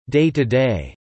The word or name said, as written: day-to-day